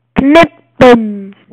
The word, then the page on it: to cut: British English